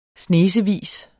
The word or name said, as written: snesevis